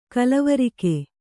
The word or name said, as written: ಗಲ್ವರ್ಕ